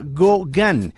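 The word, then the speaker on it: GauGAN: se idearon un sistema neuronal que se llama GoGAN. Eh, se escribe diferente, pero es homenajeando al famoso pintor Paul Gauguin.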